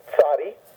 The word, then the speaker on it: Tzadi: Then it's tzadi, two-syllable word, so accent on the first syllable, tzadi, because trying to get you to go tz, tzadi.